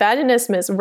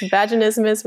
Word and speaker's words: vaginismus: I was really stumbling over the word vaginismus, and we were in our supervision together, and you said vaginismus rhymes with Christmas, and I stuck the landing, and that has stuck with me ever since. Vaginismus rhymes with Christmas, and now vaginismus is like a favorite word of mine.